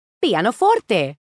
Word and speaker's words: pianoforte: And it comes from the Italian word pianoforte. Did I say that right? – You sure did.